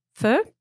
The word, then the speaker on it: pho: Yeah, say it again. – Pho? – That's it. Yeah.